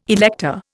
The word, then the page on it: elector: Indian